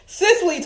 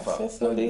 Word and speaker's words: Cicely: Solicited Tyson. – Then you gave her name wrong. Cicely Tyson. – Oh. – Okay. – Cicely.